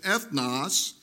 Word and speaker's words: ethnos: Now, did you catch in the Greek pronunciation ethnos what that word sounds like in the English? It's where we get the English word ethnicity, okay?